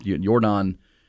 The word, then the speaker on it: Yordan: Yordan is as they pronounce it in Cuba.